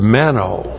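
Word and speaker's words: meno (μένω): He used the verb meno, M-E-N, long O, to abide, to remain, to stay.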